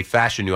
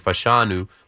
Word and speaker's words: Fashanu: I heard Kuiper say Fashanu. I've been saying Fashanu, putting the emphasis on the A-N.